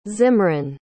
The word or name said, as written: zimran